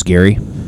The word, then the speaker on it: Gary: I mentioned my name's Gary.